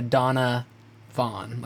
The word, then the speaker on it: Donavaughn: It is spelled D-O-N-A-V-A-U-G-H-N. Donovan Campbell. – Donovan. Donna, it sounds like two, like a Donna Vaughn, like middle name.